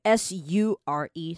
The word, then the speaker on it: sure: 好，你看到这边后面这个 sure，它就是 sure 嘛， sure 就是确定的意思。你把后面那个 ure 你想成 your，那 your 那边再加一个 s 就会变成 sure，那你这个字就可以发音发的比较标准一点。